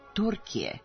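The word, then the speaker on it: Türkiye: Umesto međunarodno priznatog engleskog oblika Turkey, ova država odsad će koristiti naziv Turkije, kako se njeno ime izvorno izgovara na turskom.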